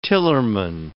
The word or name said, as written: tillerman